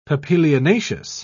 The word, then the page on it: papilionaceous: British English